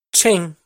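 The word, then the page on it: Tsheej: Hmong; last name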